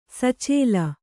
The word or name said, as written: ಸಚೇಲ